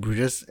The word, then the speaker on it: Bruges: But yeah, I know you're not looking forward to this because you didn't really like in Bruges, but I did enjoy it, so I'll be looking forward to this. – Any of our Belgian listeners, I believe you don't pronounce the S of in Bruges.